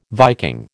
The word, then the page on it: Viking: American English